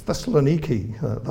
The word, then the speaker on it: Thessaloniki: I was corrected in that. I was talking about this to Henry last week, and he said it's Thessaloniki. That's the way you pronounce it, which I guess I knew already.